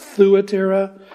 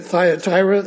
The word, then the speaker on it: Thyatira: my Greek lexicon said it should be pronounced Thuatira. I grew up pronouncing it Thyatira. I'm going to stick with that.